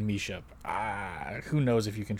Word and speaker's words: Misia: I think they pronounced her name on TV as Misha, but the artist is spelled M-I-S-I-A.